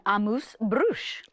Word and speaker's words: amuse-bouche: They gave me actually an amuse-bouche.